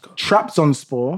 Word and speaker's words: Trabzonspor: Now, April, we had Trabzonspor. Yeah. I always don't know how to pronounce it. You did well there.